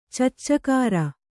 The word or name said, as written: ಚಚ್ಚಕಾರ